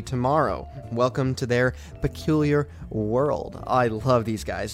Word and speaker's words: Pqlyr: Their name is Pqlyr, and it's pronounced just like Pqlyr, but it's written P-Q-L-Y-R. Again, P-Q-L-Y-R, but it's pronounced Pqlyr.